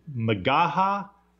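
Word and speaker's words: McGaha: Is his last name pronounced Magaha? – Yes.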